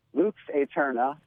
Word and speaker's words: Lux Aeterna: How do you pronounce that? – Lux Aeterna. It's Eternal Life.